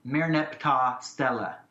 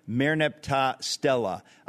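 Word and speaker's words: Merneptah Stele: There's several ways in English. I would pronounce it Merneptah. Merneptah-Stele. – Merneptah-Stele.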